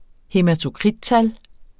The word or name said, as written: hæmatokrittal